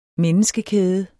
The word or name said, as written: menneskekæde